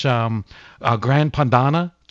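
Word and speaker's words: Grana Padano: I mean, you could take this stuff, just put it on a little bread, grate a little fresh Gran Padano cheese, if I'm saying that correctly. – And, you said it perfectly.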